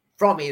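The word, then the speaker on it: Fromme: On the first day of the trial, Squeaky Frommy or Lynn Squeaky From, Frommy is the way she she likes her name to be pronounced.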